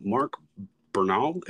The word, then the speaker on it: Mark Bernal: Mark Bernal, am I pronouncing that correct? – Yep, you got it.